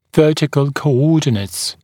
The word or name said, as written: vertical coordinates co-ordinates